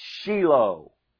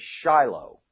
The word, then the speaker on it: Shiloh: And we have that example here with the name of Messiah called Shiloh. Now, notice I didn't say Shiloh, because the correct Hebrew pronunciation is Shiloh.